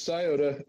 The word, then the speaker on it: Scioto: It's Scioto. – Scioto.